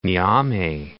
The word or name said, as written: Niamey